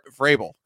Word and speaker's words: Vrabel: Everyone loves Mike McDaniel and got their nerd hard ons for Mike McDaniel's coaching when really we should be talking about Bravel because Vrabel because he's steady Eddie. Look, that's the problem. I just mispronounced his name.